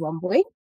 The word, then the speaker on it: Wambui: My name is Wambui. Sounds difficult, but it is pronounced as it's spelled.